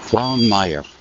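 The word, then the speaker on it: Gleann Maghair: I'm in a place called Glanmire, which is pronounced Glanmire, G-L-E-A-N-N, new word, M-A-G-H-A-I-R. That's what it is in Irish.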